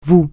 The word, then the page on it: vous: francais